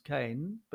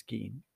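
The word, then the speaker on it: Kean: Leslie Keane, sometimes pronounced Kane, but I think it's Keane, who is, responsible for a large part for the supercharged nature of the revolution.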